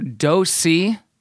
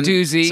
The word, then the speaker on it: Dosey: Taylor Dosey. – Yeah, not Doozy. – Someone made the comment that we pronounced it wrong. – Yeah. – Which, by the way, I'm pretty sure they say Doozy at some point, which is why I was so confused, because it's spelled like Deuce.